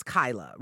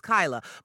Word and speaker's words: Kyla: Again, my name is clearly says that it's Kyla, right? In any other case, it's Kyla. But because people are so scared to mispronounce it, they inevitably end up mispronouncing it. So they'll be like, hey, Kayla. Hey, Kyla. Hey, Koji Jakalia.